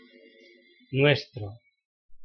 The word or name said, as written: nuestro